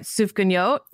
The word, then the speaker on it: Sufganiyot: The Hanukkah episode is the, wait, tell me if I'm saying it right. Sufganiyot? – Yeah. Yeah.